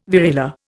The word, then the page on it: variola: Indian